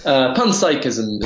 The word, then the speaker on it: panpsychism: which is panphysicism. I hope I'm saying that right. – Panpsychism. Pronunciation. That's all right.